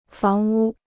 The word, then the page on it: 房屋: fáng wū